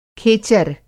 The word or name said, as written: खेचर